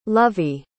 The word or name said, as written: lluvy